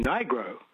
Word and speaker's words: Nigro: And one of them is pronouncing the first vowel in a word like the name of the name of the letter. So there's a record producer in America called Dan Nigro, N-I-G-R-O, N-I-G-R-O.